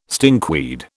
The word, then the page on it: stinkweed: British English